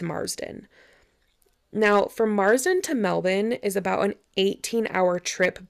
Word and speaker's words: Melbourne: which is spelled like Melbourne, but it's actually pronounced Melbourne, just saying.